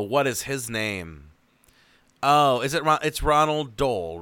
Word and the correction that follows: Roald Dahl: Ronald Dahl. Roald Dahl. I can never say his name. – Also, I know, it's hard. It's like Roald Dahl.